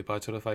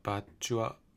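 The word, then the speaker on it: Virtua: あの友達がセガサタン持って、で、バーチャルファイター、バーチュア、バーチュア、発音変ですよね。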